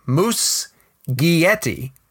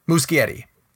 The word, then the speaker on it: Muschietti: It's there's a G-Y in the middle of it. Muschietti. And you say it fast. Muschietti.